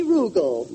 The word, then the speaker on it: Rugel: Rugel. – Rugel? – Rhymes with bugle.